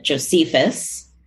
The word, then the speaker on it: Josephus: She read, I'm going to mess up his name, but she read Josephus.